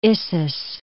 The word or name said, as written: Issus